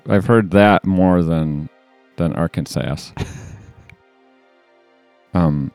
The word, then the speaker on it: Arkansas: Arkansas is pronounced Arkansas and not the way it is spelled. You know, you don't pronounce the S at the end.